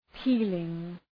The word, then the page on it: peeling: ‘pi:lıŋ